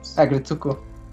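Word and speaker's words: Aggretsuko: Agretsuko.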